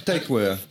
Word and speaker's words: Tykwer: Claude Atlas, le dernier film des Wachowski qu'ils ont fait avec notamment Tom Tyler. Alors, je le prononce très très mal. Tykwer. Moi, je suis nul. C'était Y-K-W-E-R.